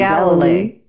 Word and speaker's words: Galilei: Galilei.